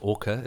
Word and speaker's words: Ake: And in this episode, we're going to chat to a man by the name of Ake Lindstrom, spelt A K E, but pronounced Orca, as in the killer whales.